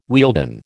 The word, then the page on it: Wealden: American English